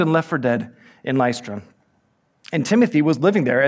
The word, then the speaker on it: Lystra: Lystra.